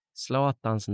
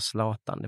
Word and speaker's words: Zlatan: Jag minns att jag uttalade Zlatans namn fel. Jag sa Zlatan, det får man inte säga, man ska säga Zlatan.